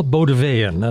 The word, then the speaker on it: Bauldeweyn: Noel Bauldeweyn, and I'll spell that for you because it's not clear. B as in boy, A U L D as in dog, E W E Y E N.